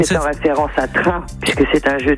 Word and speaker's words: Trun: Alors je pensais à la commune de Train, qui s'écrit T-R-U-N. – Ah, Train.